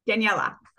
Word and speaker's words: Daniella: Daniella.